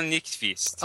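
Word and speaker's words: Nyqvist: Sorry, it's Swedish. I don't know how to pronounce it. – It's Mikael Nyquist.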